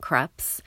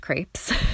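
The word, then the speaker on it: crêpes: I'm talking about crepes, also pronounced crepes. The United States, I adhere to the French pronunciation at the risk that people may not know what I'm talking about.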